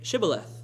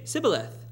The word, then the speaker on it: Shibboleth: And if he said, 'No,' then he would say to them, 'Then say Shibboleth.' And he would say Sibboleth. And for they could not pronounce it right, and they would take him and kill him at the fords of the Jordan. And there fell at the time 42,000 Ephraimites." So they had a pretty nifty plan of seeing who was an Ephraimite, is they couldn't pronounce the SH sound.